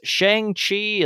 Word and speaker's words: Shang-Chi: as well as Shang-Chi and the Legend of the Ten Rings.